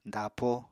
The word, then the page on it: dapo: apatani